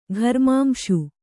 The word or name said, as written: ಘರ್ಮಾಂಶು